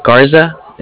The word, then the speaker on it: Garza: All right, my name is Alfonso Garza. – Mm-hmm. – My number is... – Alfonso, what's the last name? – Yeah, Garza. It's spelled G-A-R-Z-A.